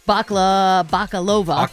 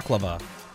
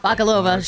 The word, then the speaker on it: Bakalova: Bakalova. – Baklava. – Bakalova.